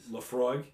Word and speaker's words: Laphroaig: Laphroaig. There you go. That one. Uh, and then his dessert is a blueberry pie. So I had to do a little bit of research. Looked up on Laphroaig, and apparently it is really potent.